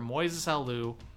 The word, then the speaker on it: Moises Alou: In the eighth inning of Game 6 of the NLCS, a foul ball that Chicago Cubs outfielder Moises Alou, did I say his name right? – Mm-hmm.